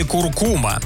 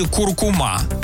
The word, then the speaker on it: куркума: Институт русского языка имени Виноградова меняет ударение в слове «куркума». Согласно большому словарю ударений русского языка, ударение на второе «у» теперь становится допустимой старшей нормой. Основной вариант отныне – «куркума». До прошлого года словари приводили только один вариант произношения – «куркума». А вариант «куркума» вовсе нигде не фиксировался.